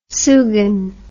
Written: sugan

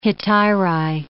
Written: hetairai

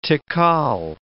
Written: tical

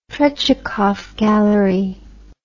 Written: Trechakov Gallery

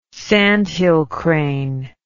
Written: sandhill crane

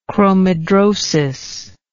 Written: chromhidrosis

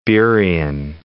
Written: Burien

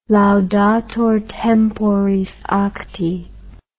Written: laudator temporis acti

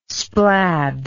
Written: splad

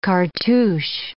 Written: cartouch

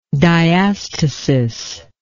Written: diastasis

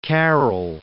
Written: Carroll

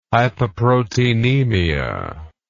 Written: hypoproteinemia

